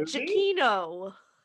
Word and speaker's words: Giacchino: That's how you say it, Giacchino.